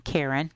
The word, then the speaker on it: Karen: It's easy, because it is my first name, Karen, K-A-R-E-N, and a dot.